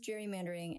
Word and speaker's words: gerrymandering: Named for Elbridge Gerry, it is pronounced gerrymandering, and I don't know that anybody has ever adequately explained to me why that is.